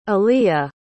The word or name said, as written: aliyya